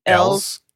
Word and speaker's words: Elwes: I'm sorry I didn't talk about Carrie Hughes in the plot, but yeah, Carrie, right? How do we say his last name? – Ells. Carrie Ells.